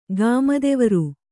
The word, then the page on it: ಗಾಮದೆವರು: gāmadevaru